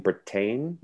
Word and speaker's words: Bretagne: And Bretagne, the Dukes of Berry and Bretagne? – Yes, Bretagne, yes.